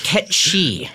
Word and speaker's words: Cait Sith: Do you know that the the pedantic, um, humorless Final Fantasy VII fans will say it's not. It's pronounced Ketchi.